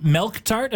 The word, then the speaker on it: milk tart: And am I saying, I just want to make sure, is it milk tart? Am I saying that right? – Yes, milk tart. – Milk tart. – Tart. Yeah, we do the thing with the R.